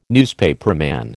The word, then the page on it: newspaperman: American English